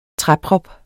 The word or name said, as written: træprop